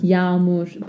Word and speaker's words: yağmur: You hear a longer A sound, yağmur, but it's in the middle as the soft G.